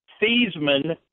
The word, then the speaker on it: Theismann: Listen, Joe Theismann changed the pronunciation of his name at Notre Dame. – Yes. – So it rhymed with Heisman, which he did not win.